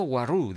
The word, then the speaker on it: Waroo: Se escribe W-A-R-O-O. Excepto Waru, dice.